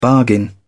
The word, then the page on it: bargain: British English